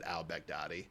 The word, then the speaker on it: al-Baghdadi: yeah, I think you actually pronounce it al-Baghdadi.